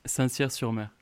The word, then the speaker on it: Saint-Cyr-sur-Mer: Saint-Cyr-sur-Mer, what was it? How did you say it? – Saint-Cyr-sur-Mer.